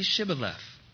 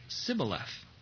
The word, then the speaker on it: Shibboleth: And if he said no, they would say to him, "Then say Shibboleth," which means divides. And he would say Sibboleth, for he couldn't pronounce it right.